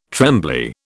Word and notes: trembly: British English

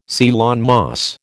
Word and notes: Ceylon moss: American English

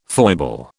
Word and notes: foible: British English

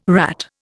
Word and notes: rat: Indian

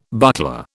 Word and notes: buckler: British English

